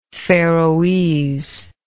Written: faroese

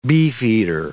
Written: beefeater

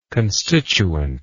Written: constituently

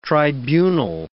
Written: tribunal

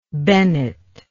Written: Bennet

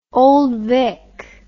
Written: Old Vic

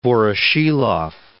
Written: Voroshilov

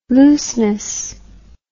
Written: looseness

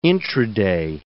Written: intraday

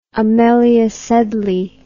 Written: amelia sedley

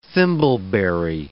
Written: thimbleberry

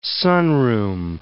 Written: sunroom

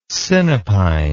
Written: sinapine

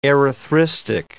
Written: erythristic

